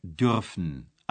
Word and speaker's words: dürfen: Il en va de même avec dürfen, avoir le droit. Voici un exemple à la troisième personne du singulier. Le U tréma est remplacé par un U prononcé OU.